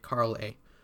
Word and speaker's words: Carley: Carly? Carley, Lyra corrects me, pronouncing it Carl A.